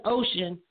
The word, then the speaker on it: Ocean: You know, I was like, oh my, that's why I put, like, her name, the goddess name is actually pronounced Oshun, but I pronounce mine as Ocean.